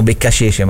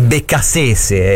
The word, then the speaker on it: Beccacece: Ieri lo chiamavamo Beccacece, ma questa è una pronuncia da insomma da fucecchio, no? È Beccasese, è il CT.